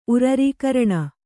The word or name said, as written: ಉರರೀಕರಣ